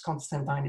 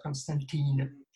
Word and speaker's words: Constantine: First, I was not overly happy that Constantine would get his own, and it's Constantine, it's not Constantine. It's written in the comic, it's pronounced Tyne.